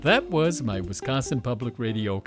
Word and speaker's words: Wisconsin: That's how people out of state say it, Wisconsin. – How do you say it? – Wisconsin, the second syllable. – I accent the con.